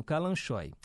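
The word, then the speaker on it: Kalanchoe: Fala calanchóis mesmo? Calanchói? Uma flor linda. Mas fiquei aqui na dúvida agora na hora da pronúncia. Obrigado aí pela sintonia. A ah, Isabel falando que é isso mesmo, Calanchoy.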